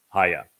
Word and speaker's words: haya: Haya explains how to pronounce the he and also the yod in Yahweh's name. It is hi, it is he, yod, he, and it is pronounced haya.